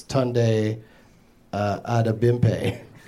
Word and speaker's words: Tunde Adebimpe: It looks like it's Tunde Adebimpe.